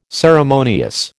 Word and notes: ceremonious: American English

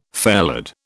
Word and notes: fairlead: British English

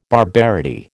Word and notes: barbarity: American English

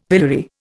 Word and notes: pillory: Indian

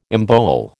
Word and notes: embowel: American English

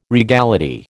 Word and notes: regality: American English

